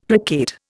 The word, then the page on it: placate: Indian